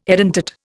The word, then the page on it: edentate: Indian